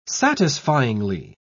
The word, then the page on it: satisfyingly: British English